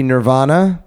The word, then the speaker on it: Nirvana: his friends argued it would be Nirvana, which I think I said it right. – You did say it right.